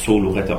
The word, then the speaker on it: Zoloretto: Wobei, also Zoloretto, also aufgrund des Themas, glaube ich, kann man Zoloretto aussprechen. – Wie habe ich es ausgesprochen? – Suloretto, ich glaube, es geht weniger um Eingeborene als um Tiergehege.